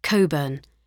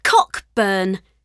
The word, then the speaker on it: Cockburn: So you're Cockburn, she said, grinning, pronouncing the hard K like the Americans did, in the hope it would annoy the Admiral.